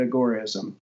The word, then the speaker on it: agorism: So Sam Konkin called it agorism. So I spell that as agorism, like Al Gore.